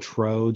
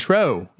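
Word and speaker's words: Truex: Ty Dillon was second, and Martin True Jr. I'm not sure if that's how you pronounce it. Tro. – Yes. – Martin Tro Jr. It's Tro.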